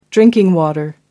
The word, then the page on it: drinking water: American English